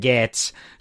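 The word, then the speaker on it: Gaetz: I said his fucking name wrong, you know. So it's Matt Gates, But I get the whole gates of hell.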